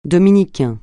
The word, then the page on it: Dominicain: francais